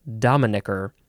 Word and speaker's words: dominicker: Yeah, you got to emphasize the first syllable, dominicker. Dominee, dominion, dominion day, and dominique.